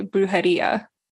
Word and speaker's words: brujería: This is going to be egregious the way that I say brujería today. – You don't have to roll it. You can just pronounce it like a D. Like you can just say brujería.